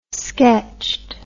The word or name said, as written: sketched